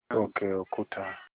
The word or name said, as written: okeokuta